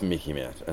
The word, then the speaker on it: MICIMATT: And so it's MICIMATT. And as he says, it rhymes with Mickey Mouse, so that to help you remember it.